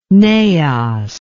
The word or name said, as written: naos